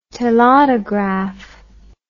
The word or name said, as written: telautograph